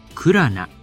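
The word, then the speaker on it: 9LANA: アーティストはクラナ、数字の 9 にアルファベットで LANA、ラナと書いてクラナと読みます。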